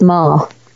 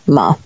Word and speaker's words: Maher: So M-A-H-E-R, but it's pronounced Mar. So there you go. Mar.